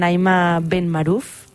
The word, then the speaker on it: Naïma Ben Maruf: Doncs ho parlem directament amb Naïma Ben Maruf. No sé, ho he pronunciat bé? – Correcto.